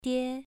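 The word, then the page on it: die: tone 2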